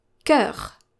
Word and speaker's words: cœur: If the ligature, so the OE, is followed by a vowel this time, you can pronounce it as a hard C or K. For example, the word cœur, which means heart, cœur.